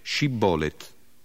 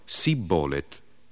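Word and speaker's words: shibboleth: Ma i Galaaditi imponevano loro di dire Shibbolet, ed essi rispondevano Sibbolet, perché non sapevano pronunciare correttamente la parola.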